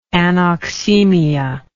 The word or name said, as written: anoxaemic